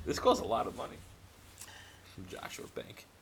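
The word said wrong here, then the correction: Jos. A. Bank: Oh, Joseph A. Bank? – Yeah, I can never say it right.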